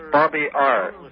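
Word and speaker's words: bobear: It's called, the infinitive is babear. Okay, babear. It means to fool around. – Huh? – And it's spelled Bob Ear.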